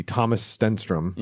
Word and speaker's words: Thomas Stenström: I'm not going to do the same pronunciation that Niklas did, but the American pronunciation would be Thomas Stenström.